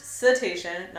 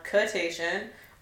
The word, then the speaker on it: cetacean: And they're pronounced cetacean, not cetacean, or crustacean, as I heard this week, which was a whole other thing.